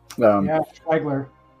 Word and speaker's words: Schwoegler: Schwoegler.